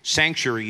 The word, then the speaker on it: sanctuary: Sanctuary, you say, how do you say it in Australia, in British English? We say sanctuary. It's like got an extra syllable in there.